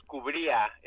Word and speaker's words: Cubria: That's very good. My mom says Cubria if you want to get very Mexican about it, but you did a good job.